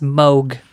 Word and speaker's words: Moog: Even though it looks like Moog, it's pronounced Moog.